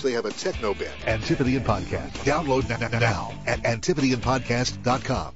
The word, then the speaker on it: Antipodean: Antipodean Podcast. That's how they pronounce it. Got to throw that tip in there. It's Antipodean.